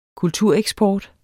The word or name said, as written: kultureksport